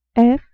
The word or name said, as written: f